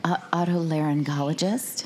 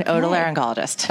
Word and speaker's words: otolaryngologist: Is that an otolaryngologist? – I am an otolaryngologist.